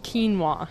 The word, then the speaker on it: quinoa: That's pronounced quinoa. And I hear it pronounced a lot of different ways, but quinoa is the correct saying.